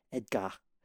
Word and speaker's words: Edgar: Edgar, but the French pronunciation, Edgar, is a great name for a goldfish, and Edgar Deception is a great name for a band.